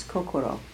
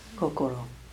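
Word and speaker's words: kokoro (心): And it's if it's used alone, it's the Japanese pronunciation is kokoro. You might have heard that word, kokoro.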